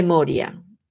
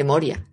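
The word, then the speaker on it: Morya: Bueno, pues, ¿qué dicen de Moria? De Moira, perdón. De Moria, lo he dicho bien.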